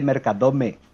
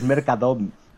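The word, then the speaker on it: Mercadona: Que Carón te dice Mercadome y me pone de los nervios. Es Mercadón. Mercadón, Caronte, Mercadón. – Depende de la zona de, Mercadón, lo pronuncian de una manera u otra. – No, no. que es el cherife y que no, no, no, es Mercadón, punto.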